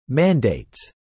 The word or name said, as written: mandates